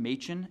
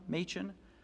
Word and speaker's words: Machen: There's a great book by a guy named Gresham Machen. It's spelled as if it was Gresham Machen.